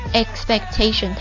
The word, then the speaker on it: expectation: The noise of family expectations，注意一下 expectation 它是由 expect 期待动词变过来的一个名词啊，读音不一样啊， expect 这个地方是 expectation， expectations 期待期望啊，我们被这个家族期待的声音， The noise of our parents，父母的声音， The noise of our brothers and sisters expectations，兄弟姐妹们期待的声音，给蒙蔽了。